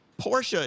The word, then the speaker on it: Porsche: Porsche is two syllables. – Correct.